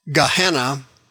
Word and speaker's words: Gehenna: Gehenna is pronounced in English, Gehenna, that's the lake of fire.